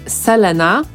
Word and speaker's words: Selena: Natomiast jej imię piszemy Selena, ale tak go nie wymawiamy, bo wymawia się je Selena. I ona sama też tak powie.